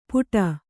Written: ಪುಟ

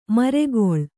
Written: ಮರಗಾಳಿ

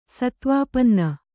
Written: ಸತ್ವಾಪನ್ನ

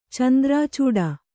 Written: ಚಂದ್ರಾಚೂಡ

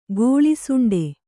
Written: ಗೋಳಿಸುಂಡೆ

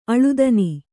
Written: ಆಳ್ತನ

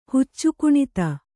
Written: ಹುಚ್ಚುಕುಣಿತ

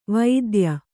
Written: ವೈದ್ಯ

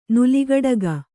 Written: ನೆಲಗುಡುಗು

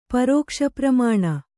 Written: ಪರೋಕ್ಷಪ್ರಮಾಣ

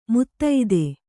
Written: ಮದದ್

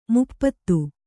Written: ಮಾಪತಿ

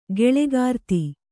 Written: ಖಳಕೃತಿ

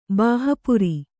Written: ಬಾಹಪುರಿ

